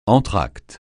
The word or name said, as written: entracte